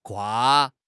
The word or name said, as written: 跨